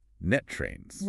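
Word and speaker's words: NTREIS: Which, for those of us operating in this specific local ecosystem, is proudly pronounced Net Trains. – Right, Net Trains. – You have to hit that rust sound at the end, right?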